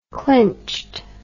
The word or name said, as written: clinched